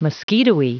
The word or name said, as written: mosquitoey